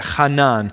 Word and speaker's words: hanan: All right, so this Hebrew term that he's talking about is the word hanan. Okay, that's how you say it, hanan.